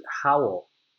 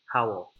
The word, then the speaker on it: Howell: I pronounce it Howell. Howell.